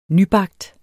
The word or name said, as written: nybagt